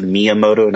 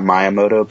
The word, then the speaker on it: Miyamoto: It's a Miyamoto, not Miyamoto, but now that we've got that figured out, we can move on.